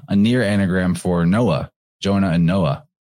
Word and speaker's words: Jonah: Another character who's lost on the waters was Jonah. And Jonah is a weird one. First of all, it sounds like if you give it the soft J, it sounds like Yona.